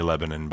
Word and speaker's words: Lebanon: He's not talking about Boone County, Lebanon, by the way, which is a two-syllable word, right? Lebanon. But Lebanon, in that day in Egypt, was known for their vast forest.